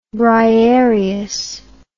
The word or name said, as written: Briarean